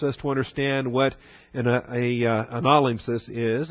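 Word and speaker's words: Analimpsis (ἀνάλημψις): Analimpsis, with the accent on the ana. Analimpsis. Analimpsis.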